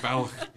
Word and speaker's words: Balloch: You're even getting the sounds from Balloch.